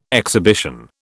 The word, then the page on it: exhibition: British English